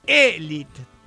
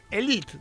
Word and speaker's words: elite: En general, pronuncian este galicismo al modo franchute, francés, elite, decimos más fino. ¿Qué dijo? Se trabó la lengua, bueno, siempre se me traba la lengua. Y me río, ¿qué voy a hacer? Pero yo lo reconozco, otro no, yo sigo. Fue españolizado, se escribe y se pronuncia con todas sus letras, elite, incipiente, y cuidado con incipiente, porque se escribe de dos maneras.